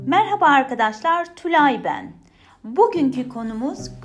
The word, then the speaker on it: merhaba: Merhaba, merhaba, merhaba. Looks like that. Merhaba. H is silent there. But when you write it down, H is there.